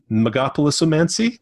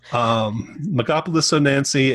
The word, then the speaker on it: Megapolisomancy: One of them is, here we go with the pronunciation, Megapolisomancy. – Sounds good. – Close enough. Megapolisomancy,